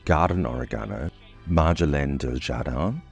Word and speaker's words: marjolaine: Marjoram in French is pronounced marjolaine and spelled M-A-R-J-O-L-A-I-N-E.